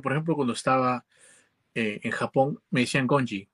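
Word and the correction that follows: gaijin: que era como especie de forastero, ¿cierto? No, lo pronuncio bien de repente. Ah, gallín será. Gallín, me decían gallín. Gallín, Gajin. gallín.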